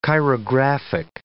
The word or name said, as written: chirographic